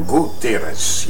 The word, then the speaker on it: Gutierrez: Did I say it right? Gutierrez. – Oh, Gutierrez, yes.